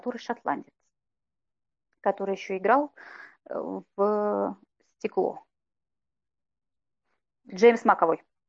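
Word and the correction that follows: McAvoy: Макэвой. Угу. Макэвой, правильно произносить его фамилию.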